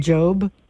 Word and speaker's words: Job: Job from the Bible, didn't they pronounce him Job? – Yes. That is correct.